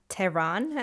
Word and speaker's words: Tehran: I'm from the city Tehran, or Tehran, as you some people want to pronounce it. It's always difficult to pronounce things. Like, if I want to pronounce it in Farsi, it's something else. And if I want to pronounce it in English, it sounds funny.